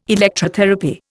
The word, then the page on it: Electrotherapy: Indian